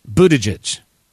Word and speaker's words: Buttigieg: Pete Buttigieg. There's your help for the day on how to say his name. B-U-T-T-I-G-I-E-G.